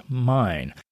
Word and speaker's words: Main: Now, it's spelled M-A-I-N, but it's pronounced mine.